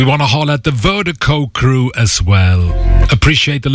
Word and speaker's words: Virdiko: And Vertigo is spelled V-I-R-D-I-K-O. Pronounced Vertigo, that's V-I-R-D-I-K-O.